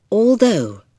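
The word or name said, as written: although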